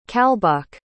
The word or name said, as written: calbhach